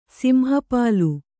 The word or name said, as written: ಸಿಂಹಪಾಲು